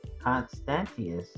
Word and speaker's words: Constantius: Constantius, is the right way to say it, outlaws all methods of divination, astrology not excluded.